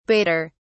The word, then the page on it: badder: British English